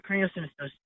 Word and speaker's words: craniosynostosis: But it actually is with how do you say it here? Help me out. Craniosynostosis. – Yeah, craniosynostosis. – It's, so uh, close.